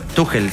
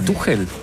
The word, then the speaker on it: Tuchel: Tuchel se pronuncia. – Ah, Tuchel. – En alemán. Es alemán.